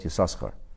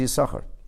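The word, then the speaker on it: Yissachar: Now you see, Yisachar is spelled with two shins, with two sins. Now there are those who mispronounce this purposely, especially Polish Yidden, they call it Yisachar. But that's not the name. The name is Yisachar.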